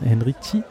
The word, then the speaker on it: Henrici: Es gab eine kleine Abschiedsrede fast schon von Christian Henrici. – Richtig, ja. Mit Z ausgesprochen.